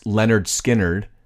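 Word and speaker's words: Lynyrd Skynyrd: Also in August, Skynyrd's album, pronounced Leonard Skynyrd.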